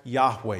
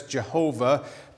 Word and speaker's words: Yahweh: It was probably pronounced something like Yahweh. You will occasionally hear it as Jehovah, but since there's no J sound in Hebrew, that's probably not right.